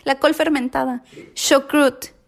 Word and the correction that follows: chucrut: Es chucrut ok.